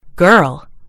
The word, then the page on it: girl: British English